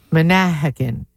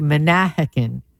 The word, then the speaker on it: Manahawkin: For Manalapan, we put the emphasis on the second syllable, so it would be Manahawkin.